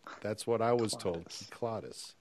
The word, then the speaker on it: Cyclades: I always wanted to say the Cyclades because that's how I would pronounce it. But when I was over there a long time ago, it was pointed out it was the Kyklades. And that's the way I've been pronouncing it ever since.